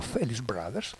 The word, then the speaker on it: Felice Brothers: come invece i Felice Brothers diventano Felice Brothers,